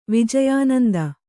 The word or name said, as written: ವಿಜಯಾನಂದ